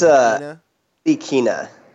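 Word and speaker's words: Ntilikina: But Nedelinka? Frank Nedelinka? – I think it's Nilikina. Nilikina.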